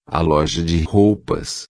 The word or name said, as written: loja de roupas